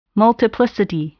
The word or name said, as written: multiplicity